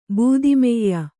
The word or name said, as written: ಬೂದಿಮೆಯ್ಯ